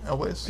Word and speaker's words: Elwes: I said that right? Right? Elwes? – Yes.